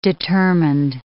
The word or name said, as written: determined